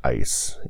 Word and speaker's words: eis: So going back to John 3:16, in is the Greek word eis. It is spelled E-I-S, and it means into.